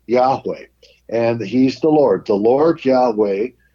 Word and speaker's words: Yahweh: The older generation used to pronounce it Jehovah, but we know it more likely it was pronounced Yahweh.